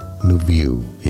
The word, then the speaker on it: Lviv: Lviv is how you say it correctly. – Lviv? I never would have guessed that. L-V-I-V.